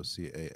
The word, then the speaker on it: Campagna: That's spelled like in Italian. So Campagna.